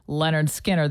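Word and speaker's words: Lynyrd Skynyrd: You know, if something is spelled kind of funny, AI will definitely mispronounce it. This is kind of tricky. Leonard Skinner, that's how you say it.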